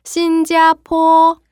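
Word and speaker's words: 新加坡: 자, 여기 포는요. P 발음이기 때문에 다시 해 볼게요. 포 발음이에요. 신자포.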